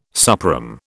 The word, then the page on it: suprÈme: British English